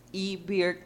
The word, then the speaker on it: eBird: eBird es una E, porque se pronuncia en inglés eBird, que quiere decir ave.